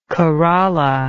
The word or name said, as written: corolla